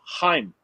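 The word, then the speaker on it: Chaim: It says Chain Bloom. Chain David Bloom is an American sports executive. – Yeah, but he was the chief baseball. – It's pronounced Heim, though.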